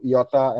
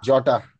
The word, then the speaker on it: Jota: And as usual, from the Wolves, Diego, Jota and Jota. – I made a mistake again, yeah.